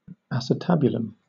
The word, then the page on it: acetabulum: British English